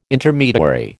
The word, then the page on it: Intermediary: American English